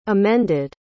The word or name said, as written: amended